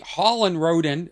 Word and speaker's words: Holland Roden: Holland Roden, am I saying it right? – Yes.